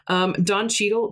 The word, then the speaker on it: Cheadle: Don Cheadle, that's how you say his name, isn't it? Yeah, he isn't credited in this movie because he wanted to be listed with Clooney, Damon, and Pitt.